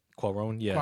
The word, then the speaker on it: Cuarón: When I saw that Alfonso Cuarón won. Did I say his name right? – Cuarón, yeah.